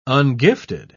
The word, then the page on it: ungifted: British English